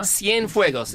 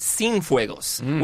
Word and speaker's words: Cienfuegos: But even then, like his name is Cienfuegos. – Uh-huh. – Cienfuegos. It stands for 100 fires. Yeah, well, they all pronounced it Cienfuegos, which means without fire.